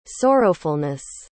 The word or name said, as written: sorrowfulness